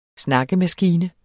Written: dels .. dels

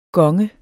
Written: gonge

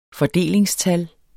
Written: fordelingstal

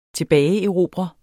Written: tilbageerobre

